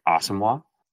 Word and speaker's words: Asamoah: Actually, it's pronounced Brian Asamoah.